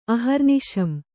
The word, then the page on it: ಅಹರ್ನಿಶಂ: kannada